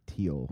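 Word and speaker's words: Thiel: Peter, I think he pronounces it Teal.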